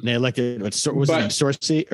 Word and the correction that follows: Morsi: or was it how you pronounce the Morsi was the Muslim Brotherhood guy.